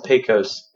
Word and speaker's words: Pecos: Looks like it should be pesos, but it's spelled wrong. – Like pico de gallo? – It's pecos.